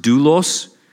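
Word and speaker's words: doulos (δοῦλος): This word is pronounced doulos, and like the Old Testament word, similarly, it can be translated slave or servant or bondservant.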